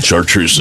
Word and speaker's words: Chartreuse: Chartreuse is how you say it.